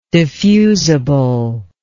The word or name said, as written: diffusibly